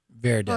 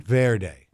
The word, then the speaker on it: Verde: Do you say Verde or Verde? – Verde. – Hold on, I'm learning Spanish. – You say it right, Verde.